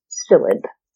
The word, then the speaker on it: psyllid: It's transmitted to the trees by an insect, the Asian citrus psyllid. Psyllid starts with a P. The P is silent.